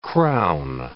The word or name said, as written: crown